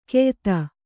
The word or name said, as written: ಕೆಯ್ದು